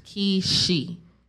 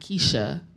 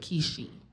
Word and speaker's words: Kishi: My name is spelled K-I-S-H-I. It's pronounced Keishi. Not Keisha, but Keishi. – Right. So think of a key and then she.